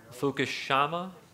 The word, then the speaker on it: Fukushima: Fukushima? That's how you pronounce it.